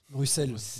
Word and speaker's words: Bruxelles: Juste une boutade, le X de Bruxelles se prononce Bruxelles.